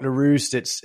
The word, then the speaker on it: Leruste: So my name's Mark Leruste. It's unpronounceable because my dad's French and nobody's perfect.